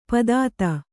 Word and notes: ಪಾಥೋದಿ: kannada

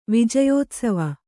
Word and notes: ವಿಜಯೋತ್ಸವ: vijayōtsava

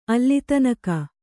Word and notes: ಅಲ್ಲಿತನಕ: Kannada

